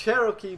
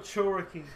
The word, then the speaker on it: Cherokee: It's Cherokee, motherfucker. I said it right, no, bitch. – Cherokee. Like you're saying churro and then key at the end. Cherokee.